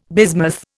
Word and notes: bismuth: Indian

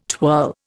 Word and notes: twirl: Indian

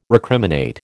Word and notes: recriminate: American English

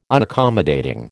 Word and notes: Unaccommodating: American English

